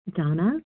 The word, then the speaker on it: Dana: This is Donna B., calling from Colorado. My name is spelled D-A-N-A, but pronounced Donna.